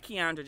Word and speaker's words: Kiaundra: All of it is at Kiaundra Jackson.